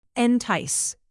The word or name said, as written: entice